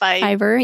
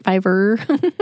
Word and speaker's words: Fiverr: It's like Fiverr, yeah. – Yeah. – But it's like 2 R's, right? Fiverr. – Yeah, that's exactly it.